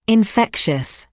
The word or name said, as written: infectious